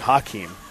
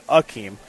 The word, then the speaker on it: Akeem: He didn't want people to say Hakeem. He wanted people to say Akeem.